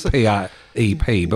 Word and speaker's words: PrEP: It is PrEP, but with a small R.